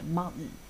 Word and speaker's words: mountain: 是 gardening 这样，就好像 mountain 山啊，它有时候会读成 mountain 这样子。